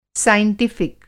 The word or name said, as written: साइंटिफ़िक